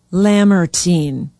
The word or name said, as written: Lamartine